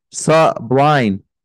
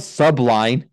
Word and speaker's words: Sublime: No, it's not subline. It's sublime.